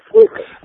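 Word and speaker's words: Selichot: But for listeners, if there are any Jewish listeners or people who have Jewish friends, they may have heard of the Slichus. That's the way that there's sort of a bastardized way of pronouncing the Slichot that the Ashkenaz would say they're going to Slichus.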